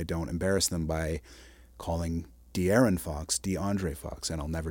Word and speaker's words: De'Aaron Fox: You had predicted Sabonis as the top performer, but in fact it was DeAndre Fox, and I hope I'm getting pronouncing his name. – DeAndre. – De'Aaron Fox.